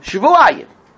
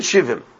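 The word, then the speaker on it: Shvuayim (שבעים): That's the way it's written. But how do we pronounce it? Shvuayim.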